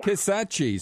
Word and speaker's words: Kisatchie: And how did you say that I should pronounce that forest? – Kisatchie. – So we're going to do a song, Kisatchie, touch on Kisatchie, as we wrap up this week's edition.